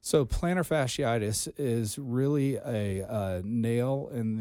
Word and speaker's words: plantar fasciitis: Of course, he suffered plantar fasciitis.